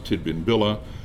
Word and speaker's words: Tidbinbilla: Another place name nearby is Tidbinbilla. Again, a series of sources, and what we find there is that the real pronunciation of Tidbinbilla is something like Jinminbili.